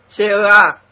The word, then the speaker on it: CEA: Cơ quan năng lượng hạt nhân Pháp CEA, đọc là tiếng Tây, CEA,